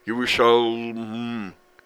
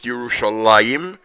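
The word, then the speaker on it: Yerushalayim (ירושלים): In other words, Yud, Resh, Vav, Yeru, Shah, Shin, Lamed, Mem, Sofit, Yerushalayim. But it's pronounced Yerushalayim as if there would be a Yud between the Lamed and the Mem.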